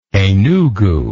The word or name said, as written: enugu